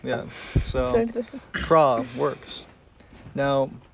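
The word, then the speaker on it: La Croix: The white people say it wrong. The Croix. That's wrong. Incorrect. – That's the French got it right.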